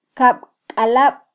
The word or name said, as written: kabkalab